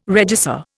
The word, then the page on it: regisseur: Indian